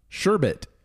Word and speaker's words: sherbet: I thought it was sherbet. – I've always called it sherbet. – Yeah, I don't know why people just started inserting an extra R into the pronunciation.